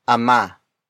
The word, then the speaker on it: ama: And that is ama. Ama. Ama. Ama is spelled as A-M-A.